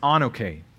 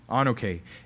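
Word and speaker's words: anoke (ἀνοχή): The word in Greek there is pronounced anoke, anoke, and it means to hold back or to delay.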